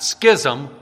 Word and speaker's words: schism: There's something called the Great Schism, and that is the correct pronunciation, not schism, schism. You don't say schizzers, you say scissors, and it's the same basic word.